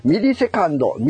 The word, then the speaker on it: ミリセカンド: 255 回で MS の、ね、読み方がわからないとのことですが、ミリセカンド、ミリ秒ですね、のことです。